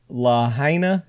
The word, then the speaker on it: Lahaina: You're in the Lahaina.